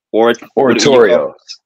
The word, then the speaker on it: oratorio: the oratios, Oratorio. Okay, I said it wrong.